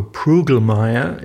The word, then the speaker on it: Prüglmeier: Uh, it's actually in, in the US, and so it's pronounced Marco Prugelmeier. Yeah? – Prugelmeier, so. just like Fel- Felgendreyer. – That's how I pronounce my last name.